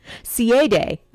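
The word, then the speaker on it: sidhe: It's S-I-D-H-E, and it's pronounced C-A-D-E. It's another one of those words that you're just like, ooh, that's not pronounced any way that it's spelled. Ciadae.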